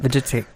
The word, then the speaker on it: Vujicic: Vujicic. You pronounce that. – Vegetic.